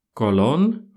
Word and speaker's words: Colón: Колон в Испании так называют Колумба. Здесь говорят Колон.